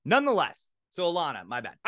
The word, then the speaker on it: Ilana: Her name's Ilana, not Lana. You had that wrong.